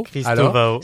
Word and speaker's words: Cristovao: Surtout que la façon dont tu prononces ton nom, on risque pas de l'écrire correctement. Vas-y, Christovao. – Christovao. – C-R-I-S-T-O-V-A-O. Voilà. Yann. – Y-A-2-N.